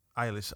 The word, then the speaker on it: Eilish: It's because you keep saying her name wrong. – Eilish. – You keep calling Irish. Her Irish.